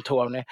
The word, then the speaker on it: Tawny: söka på T-O-N-Y på Systembolaget, utan T-A-W-N-Y. Det är väl ett efternamn, Tony, antar jag. Eh, men det uttalas, jag vet inte om det är exakt likadant eller bara väldigt snarlikt.